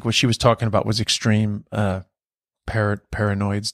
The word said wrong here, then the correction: Peyronie's: disease, Peyronie Peyronie's disease.